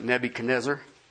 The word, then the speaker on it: Nebuchadnezzar: Some would pronounce it Nebuchadnezzar.